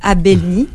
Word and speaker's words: Abelni: Voilà, et on a enlevé le qaf pour plus de facilité à prononcer. Donc c'est Abelni, A-B-E-L-N-I.